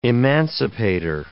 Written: emancipator

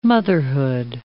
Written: motherhood

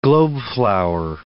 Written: globeflower

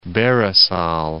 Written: Barisal

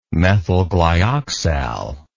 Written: methylglyoxal

